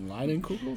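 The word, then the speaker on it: Leinenkugel: One hell of a name for a Line and Kugel. Is that how you say it? – Yeah.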